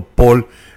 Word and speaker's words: Pohl: Paul, su mamá es de apellido Paul, no sé yo la P-O-H-L, no sé la nacionalidad de la madre, pero el apellido Paul es muy dado vaya, la forma en la que está escrito no es como se escribe en Estados Unidos, el Paul, que es Paul, no.